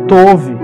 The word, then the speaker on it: Tob: O B no hebraico ele tem som de V. Então quando você vai pronunciar uma palavra que tem B, você, se, você pronuncia com o som de V. Então, ele foi para Tob, que em hebraico se diz Tove.